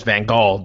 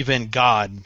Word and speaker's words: Van Gaal: Yes, Van Gaal. The Brits go with the hard G, but that's not the case. That's not. – Oh, yeah. No, I was saying Louis Van God, but yeah. – I gotcha. Well, God has a hard G, too.